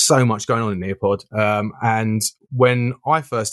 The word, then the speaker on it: Nearpod: So those of you who don't know what Nearpod is, um, in fact, I better spell it because sometimes my pronunciation isn't great. So Near, N E A R, Pod, P O D.